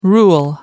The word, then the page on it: rule: American English